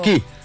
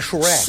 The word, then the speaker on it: Shrek: Xereque. Não xereque. É xereque, não é K. – É, exatamente. Pronto. – Perfeito. Gente, vamos fazer o seguinte, tá muito bom. – Olha, gostei. – A pronúncia é Shrek.